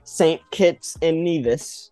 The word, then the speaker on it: St. Kitts and Nevis: On Wednesday, we have the USA versus St. Kitts and Nevis.